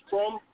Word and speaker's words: Crumb: His name is Crum. – Spell it. – C-R-U-M-B, Crum TV.